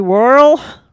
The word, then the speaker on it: Worrell: The OG Slinky Dog, Ernest P. Worrell, rhymes with squirrel, is a well-meaning but not very competent person who, maintenance man who works at a summer camp.